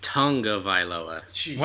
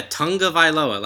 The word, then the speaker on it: Tagovailoa: The G is like an NG. – It was never Tonga. – Yeah, Tagovailoa. Like, his dad's name is spelled G-A-L-U, but it's pronounced Nalu.